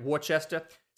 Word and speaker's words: Worcester: And looking at just this year, he spent 17 games in Worcester, that's how you say it, right? Worcester.